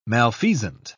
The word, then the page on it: malfeasant: British English